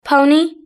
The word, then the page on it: pony: British English